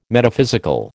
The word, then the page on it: Metaphysical: American English